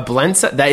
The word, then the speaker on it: blintz: So dude, Vince was asking, what a blintze? Is that a blintze? – No, a blintze. One syllable blintz. – Oh, with a Z? – Yeah. B-L-I-N-T-Z-E. Blintz.